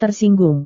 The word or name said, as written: tersinggung